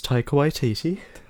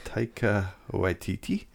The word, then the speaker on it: Taika Waititi: I'm pretty sure it's Taika Waititi. – Taika Waititi.